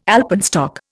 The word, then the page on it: alpenstock: Indian